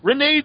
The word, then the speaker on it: Rene: And the person who did that shoot interview pronounced his name Reini.